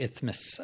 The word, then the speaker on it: Isthmus: And so that was the problem of getting over the Isthmus of Panama.